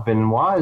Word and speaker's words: Benoit: Is it Benoit? Benoit is the right pronunciation? – Yes.